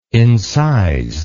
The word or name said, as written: incised